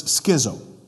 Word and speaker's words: schizo: If we were pronounce it, to pronounce it in English, it's schizo, um, which means split.